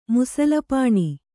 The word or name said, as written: ಮುಸಲಪಾಣಿ